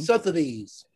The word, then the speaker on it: Sotheby's: Am I pronouncing that right? I know the name. – Sotheby's. – Yeah, right.